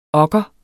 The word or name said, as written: okker